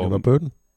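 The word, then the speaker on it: LeVar Burton: Øh, det vil sige, at Lever Button øh, kommer tilbage som George LaForge. Og Lever Burton? – Hvad? Burton. – Burton. Tim Burton? Lever Burton. Er dårligt til at udtale hans navn.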